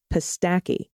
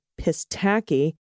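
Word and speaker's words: Pistakee: We had been pronouncing it Pistacki until we ran into a local who asked where we were staying. With much disdain, he corrected us and let us know the correct pronunciation is Pistacki with a extra nasally Midwestern long A. Pistacki. Oh, gotcha. In the middle syllable.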